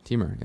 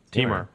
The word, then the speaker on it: Timur: Timur. Teamer. Timur. – Teamer. – Like a femur, but with a T.